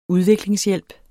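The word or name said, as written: Udviklingshjælp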